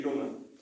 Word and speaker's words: runga: Ki runga. So ki, spell it for me. – Te-a, ki. Runga, as in big runga. R-U-N-G-A, ki runga.